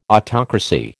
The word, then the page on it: autocracy: American English